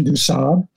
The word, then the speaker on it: du Sable: I've learned the French pronunciation, du Sable.